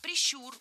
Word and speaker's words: прищур: Не прищур, а прищур. Ударение в этом слове ставим на второй слог. Да там его и оставляем во всех формах.